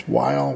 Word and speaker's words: VVHILE: This is, I believe you pronounce it Vile, but it's capital V, capital V-H-I-L-E. It looks like a W, but they're actually two capital Vs. Anyway, it's Vile with their song Vile Things.